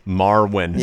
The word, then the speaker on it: Marwen: Marwen. – Yeah, whatever. Marwen. – Marwen.